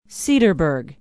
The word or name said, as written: cedarburg